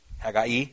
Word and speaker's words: Haggai: How about, and everyone says this name differently, Haggai. Everyone says it Haggai, I think, is how it's usually pronounced. Well, he wrote Haggai, of course, right? Haggai, if you say it right.